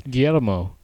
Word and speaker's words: Guillermo: Yeah, it's almost like two syllables. – Guillermo. – Make it two syllables. – Guillermo. – Yeah, it's like three, but Guillermo. – But try to make it two.